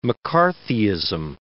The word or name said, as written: McCarthyism